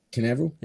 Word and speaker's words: Canaveral: TESS lifted off from Cape Canaveral, is that how you say it, in Florida? – Canaveral.